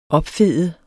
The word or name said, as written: opfede